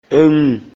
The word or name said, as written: Ŋùmɛ̀